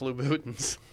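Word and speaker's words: Louboutin: I just want blood on the bottoms, you know, Louboutins. You don't know. You wouldn't, you know. – It's pronounced Louboutins.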